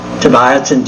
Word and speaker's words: Tobiassen: At that time, this is 1926, it's still in German, and my name is spelled Tobiasen, T-O-B-I-A-S-S-E-N, because that's the way the original immigrants all spelled Tobiasen.